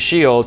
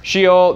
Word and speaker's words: Sheol: It says, Sheol, this is how you say it in Hebrew. It's Sheol, actually, but Sheol, underworld, abode of the dead.